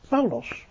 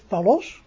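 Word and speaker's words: Paulos (Παῦλος): En die richtlijn is, als een woord twee lettergrepen heeft, dan legt u de klemtoon op de eerste lettergreep. Dus, Paulos, doulos, dus niet palos of doulos, nee, op de eerste lettergreep.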